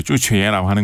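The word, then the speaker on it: 쭈취에라고 (朱雀): 예, 그 주작을 이제 중국식으로 발음하고 이제 쭈취에라고 하는 거고요.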